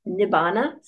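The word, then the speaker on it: nibbāna: In Pali, nirvana is pronounced nibbana, so you sometimes see it that way.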